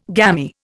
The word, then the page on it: gammy: Indian